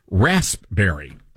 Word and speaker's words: raspberry: No, that's not how you say it. It's rasp. Raspberry. – Oh, yeah. – There's a P there. Rasp.